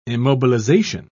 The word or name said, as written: immobilization